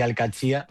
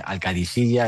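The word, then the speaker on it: Al-Qadsiah: Bueno, se suele decir Al-Qadsiya, pero también hay gente que lo dice Al-Qadisiya. Es que es difícil, ¿eh? No es fácil, pero Al-Qadsiya es lo más fácil.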